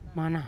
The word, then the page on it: mana: Yawa